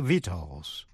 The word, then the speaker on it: victuals: Và shus, T-I-O-U-S, như trong chữ conscientious, là tận tâm. Và phần cuối là si câm, tức là không phát âm, như trong chữ vitals. Vitals, V-I-C-T-U-A-L-S, là thực phẩm, food or nourishment.